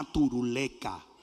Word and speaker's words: turuleca: Turulata, gallina turuleca. Ahora sí lo dije bien.